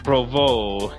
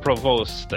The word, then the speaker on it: Provost: On this day in Habs history, On this day, September 17th, the year of our Lord, 1933, Claude Provost, not Provost, as my Ontarian tongue would pronounce it, was born in Montreal.